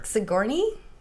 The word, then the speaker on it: Sigourney: Sigourney? – Yeah, that's it.